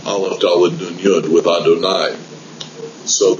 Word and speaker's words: Adonai: And so you will sometimes see in the printing that that's the way the name is printed, Yud Yud, and everybody knows to pronounce it Adonai, because it is the Tetragrammaton mixed with the Aleph, Dalet, Nun, Yud.